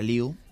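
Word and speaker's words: Liew: Bueno, aunque se lee Lieb, se pronuncia Liu.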